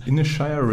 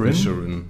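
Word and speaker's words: Inisherin: Aber jetzt habe ich gesehen, dass diese Woche schon äh, The Banshees of Inshire startet. Und ich habe das hundertprozentig falsch ausgesprochen. Mhm. Da heißt es Inishirin. Inishirin. Inishirin.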